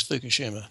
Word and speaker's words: Fukushima: We have this catastrophe at Fukushima. Am I pronouncing this right? Fukushima? – Yes, Fukushima. Mm-hmm.